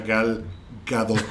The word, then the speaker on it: Gal Gadot: No, no, no, no, que por cierto eh, se pronuncia Gal Gadot.